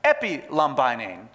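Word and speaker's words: epilambanein: It's pronounced epilambainein.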